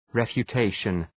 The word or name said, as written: refutation